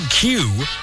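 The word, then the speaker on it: queue: The word Q, spelled Q-U-E-U-E, Q-U-E-U-E, is pronounced the same when you just leave out the last four letters.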